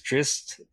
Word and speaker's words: Drizzt: I always thought it was Drizzt. Is Drizzt, is that the official pronunciation? – Yes.